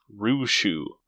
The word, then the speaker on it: Rushu: Rishu just feels that thump. – Rushu. – Oh, R U S H U. I don't know where you get these pronunciations.